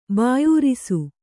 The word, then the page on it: ಭಯರಸ: kannada